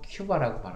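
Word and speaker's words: Cuba: 사람은 뭐 큐바라고 발음을 하더라고요. – 네, 큐바라고. 예. 예. – 저는 그냥 뭐 아직 그 익숙하지 않아서 그냥 쿠바라고 하겠습니다.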